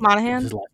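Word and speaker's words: Monaghan: You know what I'm saying, like, you could have had Cameron, like, how do you pronounce his last name? – Monahan? – Yeah.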